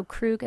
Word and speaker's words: Krug: And so Krug is spelled K-R-U-G, and Benjamin is like the boy's name.